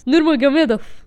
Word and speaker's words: Nurmagomedov: Nurmagomedov.